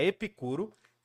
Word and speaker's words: Epicuro: que pronuncia Epicuro, mas enfim, é Epicuro.